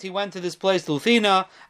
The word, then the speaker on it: Lucena: which is spelled L-U-C-E-N-A, and is actually, the C is pronounced like a th. It says Luthina. Luthina, which is southern Spain.